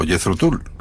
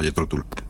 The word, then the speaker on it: Jethro Tull: O Jethro Tull. Ya sabes que se puede pronunciar de las dos maneras. Aquí en España siempre se le ha llamado Jethro Tull. – Ajá. – Así que nos quedamos con Tull.